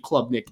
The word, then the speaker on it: Klubnik: I think Clemson is, again, a very good team in 2023, but I am selling the Heisman hype for Cade Klubnik in 2023. – Hell of a pronunciation there, by the way. That's probably one of the worst names to pronounce. – That's how you pronounce it.